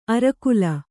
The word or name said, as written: ಅರೆಕಾಲ್